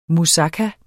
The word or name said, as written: musaka